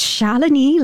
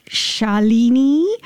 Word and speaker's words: Shalini: So it's Shalini, like felony, you know, not Shalini. – Yeah. – Oh, Shalini, you know, as some people might say, no.